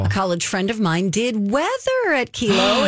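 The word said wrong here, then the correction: KELO: in the morning. – It's Kello, honey. You're saying it wrong.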